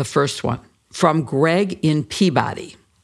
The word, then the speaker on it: Peabody: Before you answer, if Greg is from Massachusetts, it's pronounced Peabody.